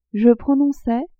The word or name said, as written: je prononçais